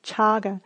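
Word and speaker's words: cāga: spelled C-A-G-A. And in Pali, C is pronounced ch, so chaga.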